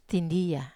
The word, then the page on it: tindia: wamesa